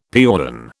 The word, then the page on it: piäon: British English